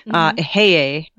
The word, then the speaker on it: Eheieh: E-H-E-I-E-H. – Oh, yeah. – Ehie? Is that how you say it? – Ehie? That's how I say it when it's one of the divine names.